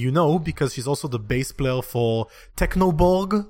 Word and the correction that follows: Chernobog: or however you pronounce that band's Chernobog. – Name. Chernobog, exactly.